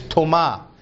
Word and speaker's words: Toma: The way you would pronounce it in Aramaic is Toma, T-O-M-A, Toma.